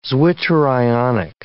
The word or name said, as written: zwitterionic